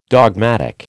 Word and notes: dogmatic: American English

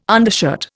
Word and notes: undershirt: Indian

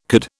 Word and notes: could: British English

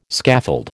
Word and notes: scaffold: American English

